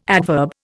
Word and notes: adverb: Indian

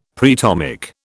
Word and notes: preatomic: British English

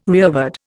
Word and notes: rearward: Indian

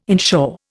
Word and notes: inshore: Indian

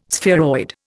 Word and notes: spheroid: Indian

